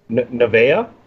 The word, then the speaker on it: Nevaeh: Alicia Edwards, Havoc, Kira Hogan, Kimberly, Kylie Rae, my girl, and boy, oh boy, how am I saying this one here? Nevaeh? Yeah? – Looking at you, phenomenal one. – You said it right, yeah.